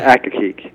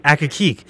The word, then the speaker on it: Accokeek: Two Lanes to Two Lanes to Accokeek. – Accokeek.